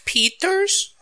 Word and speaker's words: Pieters: I didn't pronounce either of their names right. Thomas Pieters. Pieters. And Alexander Norlin.